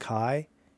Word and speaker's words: Kai: I guess it's pronounced Kai, K-A-I.